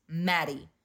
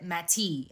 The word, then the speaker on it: Mattie: So my name is spelled M-A-T-T-I-E, right? But it's pronounced like it has D's. Maddie.